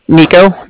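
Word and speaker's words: Niko: Nico. That's N-I-K-O.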